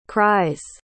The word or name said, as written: cries